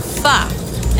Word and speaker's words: pho: Which country is the origin of pho? And that's spelled P-H-O, pho.